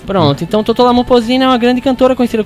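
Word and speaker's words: Totó La Momposina: Que é o som de Totó La Mopocina, falei certo? – Totó La Mopocina, isso mesmo.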